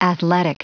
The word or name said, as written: athletic